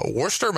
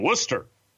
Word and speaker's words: Worcester: It's spelled W-O-R-C-E-S-T-E-R, Worcester. – There's no H in there.